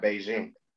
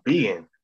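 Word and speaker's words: Bigen: It's not Beijing? – No, it's Began. – That's how you say it? – Yeah, Began. – That's how it's spelled. I know. I spell it right. I might say it wrong, but I know how to spell it. Because anytime I tweet about it, I look up how to spell it. It's B-I-G-E-N.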